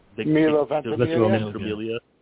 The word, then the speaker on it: Ventimiglia: Milo Vent, how do you say is Ventimiglia? – Milo Ventimiglia.